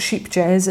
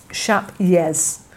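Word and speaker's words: Scheepjes: it's not sheepy jazz, it's not ship jazz, it's shap yez. So the J is a Y, and rather than sheep, it's more like shap, like shape. Shap yez is how you pronounce it, apparently.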